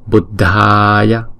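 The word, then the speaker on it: Buddhāya: Buddha đờ chữ H chúng ta đọc là đờ. Và chữ A có dấu gạch ngang chúng ta kéo dài hai nhịp. Buddhaya.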